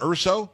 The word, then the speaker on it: Urso: Is it Urso? Am I pronouncing it right? – That's correct. Yes, sir.